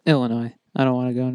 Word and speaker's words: Illinois: Do you just pronounce the S in Illinois? – Probably.